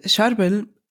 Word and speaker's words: Charbel: Charbel.